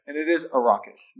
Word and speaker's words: Arrakis: Frank Herbert says Arrakis because it is the landscape is based on Iraq, the country.